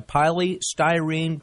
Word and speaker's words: polystyrene: Invented them all, and I guess his first invention, when they put them on the map, was the egg carton, a polystyrene, am I saying that right, styrofoam egg carton. – You're saying it right.